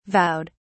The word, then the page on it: vowed: British English